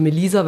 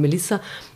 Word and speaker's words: Melisa: sagen die Leute Melisa, weil ein S, aber eigentlich sagt man Melissa, mm, ist ja okay, ja, man kann es ja nicht wissen, dass es im Bosnischen keine Doppelkonsonanten gibt.